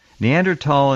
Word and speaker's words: Neanderthal: Neanderthal intelligence, and it is pronounced tall like a lot of people, was not anything close to what we had.